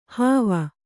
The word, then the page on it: ಹೂವು: kannada